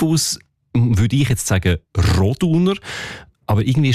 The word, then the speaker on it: Roduner: Also üblicherweise betonen wir ja Namen in der Deutschschweiz, wo drei Silben haben, immer auf der ersten Silbe. Also Andereck, Zollinger, Bertschinger, Ochsenbei. In dem Fall ist es aber eher die zweite Silbe, die betont Roduner.